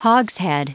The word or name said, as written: hogshead